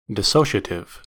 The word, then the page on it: dissociative: American English